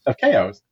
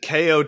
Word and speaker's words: Chao: Of Chaos or Chao's. How do we say it? – Chao. I said KO too until I heard it in the game.